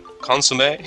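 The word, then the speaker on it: consommé: Am I pronouncing consomme? – Oh, very nice.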